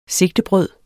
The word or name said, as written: SIGTEDES